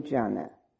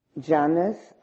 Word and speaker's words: jhana: It's spelled J-H-A-N-A. And so we can dispense with that long and cumbersome translation, which is the correct translation, and just use this two-syllable word.